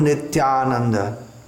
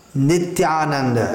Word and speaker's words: Nityananda: Sri Krishna Chaitanya Prabhu Nityananda. Again, every sound should be clearly enunciated. Commonly, native English speakers pronounce Nityananda as Nityananda. It's not Nityananda. Nityananda. It's also not Nityananda.